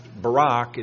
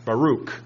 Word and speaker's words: Baruch: But it's not pronounced Barack, it's pronounced Baruch.